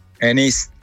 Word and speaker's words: Enix: ¿Qué es, Enix o Enix? – Enix. – Enix, vale. – Lo digo bien, ¿no? Sí, lo has dicho bien. – Terminado en X.